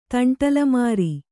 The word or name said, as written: ತಂಟಲಮಾರಿ